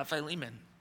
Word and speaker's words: Philemon: 1106, don't be afraid to look up Philemon in the index otherwise, and it's spelled P-H-I-L, like Phil, Philemon.